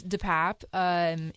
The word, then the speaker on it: DePape: So, first of all, David DePape, which we now know the pronunciation of his last name, it is DePape.